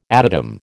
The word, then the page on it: adytum: American English